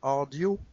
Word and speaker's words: Rdio: Euh, un service qui s'appelle en, bon français, ça fait RDIO, euh, mais euh, si j'ai bien compris, ça se prononce euh, Ardio.